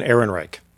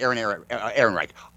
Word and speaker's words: Ehrenreich: I believe it's Alden Ehrenreich. – That's right, Ehrenreich.